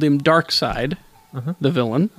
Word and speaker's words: Darkseid: See, I was, it's Darkseid. – Yeah, I always call him Darkseid. – Jack Kirby created him. Jack Kirby pronounced it Darkseid.